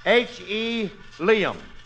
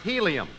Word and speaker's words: helium: H.E. Liam. – Curly, that's pronounced helium.